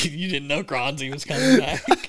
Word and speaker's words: Grans: I believe it's pronounced Helge Grans. – Granzi. Granzi. That- that's right.